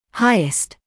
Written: highest

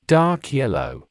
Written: dark yellow

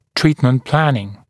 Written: treatment planning

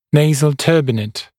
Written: nasal turbinate

